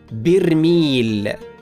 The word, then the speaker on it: برميل: من الكلمات المتداوله في نشراتنا الاقتصاديه برميل البترول هكذا تنطق برميل بكسر الباء ولكن البعض ينطقها بغير ذلك وهذا خطا شائع والصواب نطقها بكسر الباء